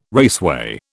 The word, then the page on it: raceway: British English